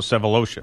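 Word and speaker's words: Sefolosha: We had the situation here with was it Sevelocious? Is that how, am I pronouncing this right? – Yeah, Favo Sevelocious.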